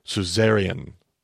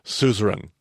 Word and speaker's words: suzerain: I used to say suzerian, uh, but I found out today, in fact, that I've been pronouncing that wrong this entire time. It's actually suzerain.